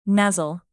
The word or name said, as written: nuzzle